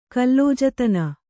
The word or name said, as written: ಕಲ್ಲೋಜತನ